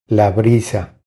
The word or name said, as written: La brisa